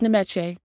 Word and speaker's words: Nyamekye: I have to say, no, it's Nyamekye. Nope, Waliyaya. That's how you say it.